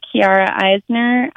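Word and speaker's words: Chiara Eisner: Kiara Eisner, I should get it. – Okay, and your name is spelled C-H-I-A-R-A, mm-hmm. and then Eisner is E-I-S-N-E-R.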